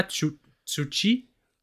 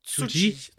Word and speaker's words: Tsuji: Yuya Yumera et Yota Tsuchi. Tsuji. Tsuji.